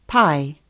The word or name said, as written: pai